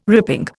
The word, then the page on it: ripping: Indian